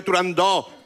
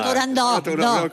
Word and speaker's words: Turandot: Yo también lo digo mal. Si es que son muchos años diciéndolo mal. En el mundo exótico en el que vive Turandot, con O abierta. – Turandot, con O abierta.